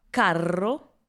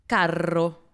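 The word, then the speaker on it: carro: Например, слово «каро» означает «дорогой», а вот слово «карро» с двумя буквами «р» означает «повозка». Слушаем еще раз. – «Каро», «карро».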